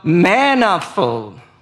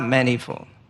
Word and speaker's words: manifold: So that through the church, the manifold, not manifold. I've been told like 7 times.